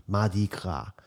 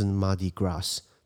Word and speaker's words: Mardi Gras: 那呃，所以路易斯安那它有很多的法国的那种风情，比如说在路易斯安那有一些节日，比如说在纽奥良他们会办这个马迪卡，马迪卡呢就是怎么翻译呃怎怎么怎么念呃怎么拼 M A R D I，然后 Gras G R A S，那你说为什么不是 Mardi Gras？对不起，因为法文的 S 不发音，除非是两个 S 放在一起。